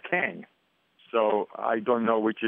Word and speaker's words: Kang: I mean, we were told that you pronounce it like Gong, like the Gong Show.